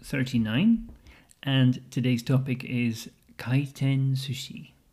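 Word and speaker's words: kaiten sushi (回転寿司): And the sushi is usually pronounced sushi, kaiten sushi, rather than sushi. So the S changes to a Z sound.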